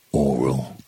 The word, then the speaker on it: aural: Is it all or aural? Do you actually pronounce it aural? Because I find it really uncomfortable saying aural. I do, and here is where I should have the sound effects come in. I do have trouble with my R's. – Oh. I don't, because you pronounce audio as in audio. So you pronounce it as O-R, even though it's spelled A-U, so I pronounce it oral.